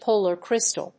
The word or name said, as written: polar crystal